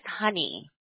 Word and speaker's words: Honey: My name is Honey.